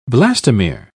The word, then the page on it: blastomere: British English